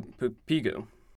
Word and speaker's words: Pigou: This is called the Pigouvian tax after the economist Arthur Pigou. It's actually how you pronounce it.